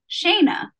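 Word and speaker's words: Shana: And one of the things that's interesting about my name is that it's pronounced as if there's a Y or an I after the first A, but it is not spelled with a Y or an I. So I have to spend a lot of time telling people how to say my name, Shayna.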